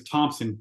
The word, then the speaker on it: Thomson: So the DT team, and if you ever Google me, the story of my life, my last name is Thomson, but I do not have a P in my last name.